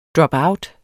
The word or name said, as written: dropout